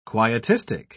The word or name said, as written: quietistic